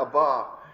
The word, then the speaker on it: Abba: I pronounce it that way. That's the Hebrew way of saying Abba, Abba.